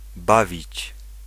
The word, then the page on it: bawić: /ˈbavit͡ɕ/